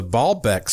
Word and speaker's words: Baalbek: One thing that Nick talks about, he mentioned the Baalbek stones. That's spelled B-A-A-L-B-E-K, Baalbek.